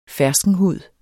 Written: ferskenhud

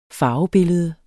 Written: farvebillede

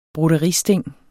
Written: broderisting

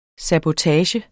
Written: sabotage

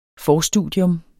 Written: forstudium

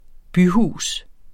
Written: byhus

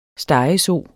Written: stegeso

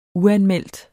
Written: uanmeldt

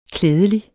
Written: klædelig

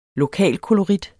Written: lokalkolorit

